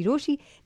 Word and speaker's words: Rochie: मेरा नाम है मिर्ची रोशी, नाम के स्पेलिंग है ROCHIE और मेरे साथ लाइन पर राजीव है.